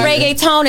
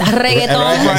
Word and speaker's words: reggaeton: He said reggaeton. It's reggaeton.